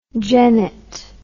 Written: genette

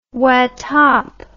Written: watap

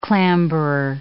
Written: clamberer

